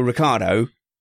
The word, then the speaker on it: Ricciardo: And I think, Matthew, that Daniel Ricciardo, and I said it right, I did,